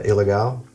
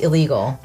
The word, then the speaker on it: Ilegal: It's not pronounced illegal. – Right. – It's pronounced ilegal because it's Mexican.